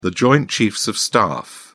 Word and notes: joint chiefs of staff: British English

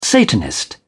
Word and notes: satanist: British English

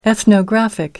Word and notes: ethnographic: American English

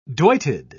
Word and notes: doited: British English

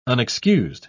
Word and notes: unexcused: British English

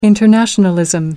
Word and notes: internationalism: American English

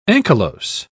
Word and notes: ankylose: British English

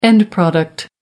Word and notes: end product: American English

